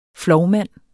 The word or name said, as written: FLOVMANDEN